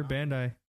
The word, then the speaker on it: Bandai: They're Bandai.